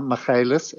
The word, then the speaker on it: Magalhaes: I think he pronounces Magalhaes, M-A-G-A-L-H-A-E-S.